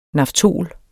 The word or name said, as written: naftol